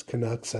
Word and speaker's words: Canucks: It's Canucks, I know. I mispronounced it on purpose because I'm a silly goose.